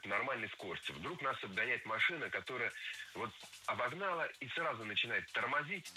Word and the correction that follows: обогнала: Обогнала, неверно, правильно, обогнала. Опять же, ударение ставим на окончание, на традиционное для этой формы место.